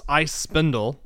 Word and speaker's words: iSpindel: How do you pronounce that? – It's ice spindle. – Okay, ice spindle. It's I guess in German they spell it S-P-I-N-D-E-L. So I always call it the spindel.